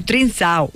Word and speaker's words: Trincão: Le pregunté a ChatGPT cómo se pronunciaba y dijo Trincão. – Trincao. Lo conocemos mejor como Trincao.